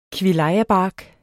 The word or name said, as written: KVILLAJABARK